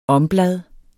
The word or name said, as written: omblad